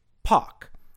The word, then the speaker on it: park: So, according to received pronunciation, C-A-R is pronounced something like ka, because the R comes after an A, and that R isn't followed by another vowel. Similarly, P-A-R-K would be pronounced something like pock.